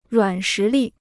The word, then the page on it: 软实力: ruǎn shí lì